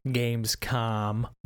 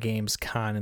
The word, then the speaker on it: Gamescom: So I appreciate the feedback because I thought I was saying Gamescom, but I guess I was saying Gamescon and like conmen. – It's actually Gamescom. Or maybe convention. – Convention. The games convention. Anyways, I apparently was pronouncing it wrong.